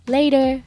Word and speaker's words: later: この later のこの発音なんですけどえっ、と later のスペルなんですけど LATER ですよね